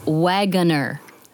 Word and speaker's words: Waggoner: It is Wagner. Three syllables. Say the name or say the word wagon and you will say it properly.